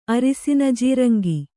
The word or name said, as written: ಅರಿಸಿನಜೀರಂಗಿ